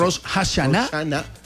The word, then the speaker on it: Rosh Hashanah: Rosh Hashanah.